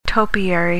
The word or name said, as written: topiary